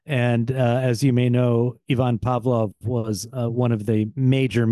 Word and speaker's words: Ivan Pavlov: And I love that you pronounce his name correctly, Ivan Pavlov.